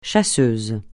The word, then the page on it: chasseuse: français